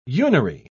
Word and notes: unary: British English

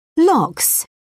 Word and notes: lox: British English

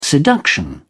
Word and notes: seduction: British English

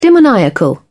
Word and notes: demoniacal: British English

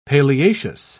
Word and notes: paleaceous: British English

